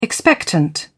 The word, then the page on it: expectant: British English